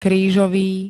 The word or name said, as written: krížový